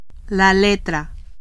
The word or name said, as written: letra